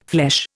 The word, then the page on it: flesh: Indian